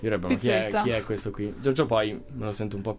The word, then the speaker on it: Giorgio Poi: Invece con Elio e con Giorgio Poi, quindi il featuring piacerebbe con, l'ho detto giusto, no? Con Giorgio Poi,